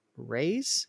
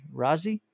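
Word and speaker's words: Raize: I think you pronounce his last name Ray's. It's R A I Z E Razi, and DB Sweeney, just to name a few.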